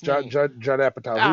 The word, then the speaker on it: Judd Apatow: Yes, Judd Apatow.